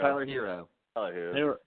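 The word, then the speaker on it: Tyler Herro: Tyler Hero. Hero? Hero? – Tyler Hero. – Tyler Hero.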